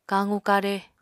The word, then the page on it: kaqu-kare: Apatani